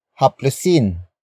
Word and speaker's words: haplusin: Word for word it's haplusin mo ang ulo ng sanggol. Haplusin mo ang ulo ng sanggol.